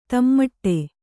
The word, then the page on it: ದಮ್ಮಡಿ: dammaḍi